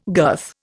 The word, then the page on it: girth: Indian